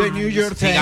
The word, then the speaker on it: The New York Times: The New York Times. – The New York Times.